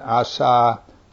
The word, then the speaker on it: Asa: And Asa, or Asa if you like the long A, Asa his son reigned in his place.